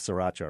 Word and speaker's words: sriracha: In some of the Asian restaurants, the popular condiment, the big red bottle, it's spelled S-R-I-R-A-C-H-A.